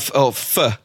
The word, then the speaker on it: pho: So it is, or pho, as it's pho. Supposed to be pronounced, I think.